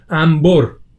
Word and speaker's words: انبر: همین قضیه که مثلا نون قبل از ب رو همیشه ما میم تلفظ میکنیم، انبر، انبار و از این جزئیات، درسته؟